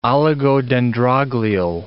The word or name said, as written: oligodendroglial